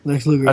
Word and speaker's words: Lex Luger: Lex Luger.